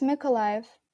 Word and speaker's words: Mykolaiv: Yes, Mykolaiv.